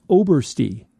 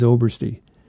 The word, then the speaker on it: Oberst: A listener named Dan Oberst, he gave me the pronunciation. It's Obersty. And now that I see it, I think I was wrong all these years. Like, yes, it is Obersty.